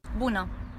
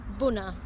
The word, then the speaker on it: Bună: Bună. – Bună.